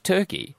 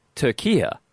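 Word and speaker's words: Türkiye: Oh, no, it's not Turkey. Turkey is pronounced Turkia.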